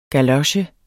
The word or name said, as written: GALOCHES